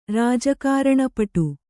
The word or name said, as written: ರಾಜಕಾರಣಪಟು